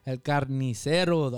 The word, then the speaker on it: El Carnicero: Best known by his nickname, El Carnes, El Carcinero, The Butcher. – El Carnicero, The Butcher.